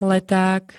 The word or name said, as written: leták